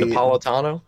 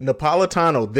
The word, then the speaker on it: Napolitano: But to get back on this whole DACA thing, the former Homeland Security Secretary Janet, and I'm going to murder her last name, Napa Latina, whatever. Janet, that's what we're going to call her. If you know how to say it better than me, say Napolitano. – It. Napolitano. There you go.